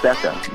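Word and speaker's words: Gbeke: she's African, so her name is Becca. Just Becca. – Okay, Becca. – Like the G is silent. It's G-B-E-K-E, but the G is silent.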